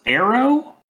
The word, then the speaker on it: Aaero: On the Xbox One, from October 1st to October 31st, the entire month, you can get, I believe it is pronounced Arrow? A-A-R-O? A-A-E-R-O. – A-A-R-O.